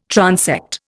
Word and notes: transect: Indian

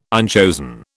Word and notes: unchosen: British English

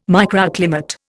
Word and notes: microclimate: Indian

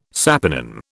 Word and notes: saponin: British English

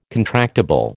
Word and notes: contractable: American English

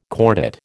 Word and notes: cordate: American English

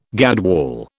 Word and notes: gadwall: British English

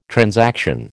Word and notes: transaction: American English

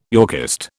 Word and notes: Yorkist: British English

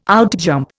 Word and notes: outjump: Indian

